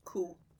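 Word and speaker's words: cú: You would expect coo to be cow, but it's not. Bo is cow with a little accent on it. So it's a long O. Bo is cow. And coo, with an accent on the U, is just C-U, which...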